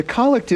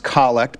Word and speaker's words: collect: And it's pronounced collect, but we might think of it as collect because it's the prayer where the priest collects everybody's individual intentions, and with a single voice, he's standing in the person of Christ, the head of the church.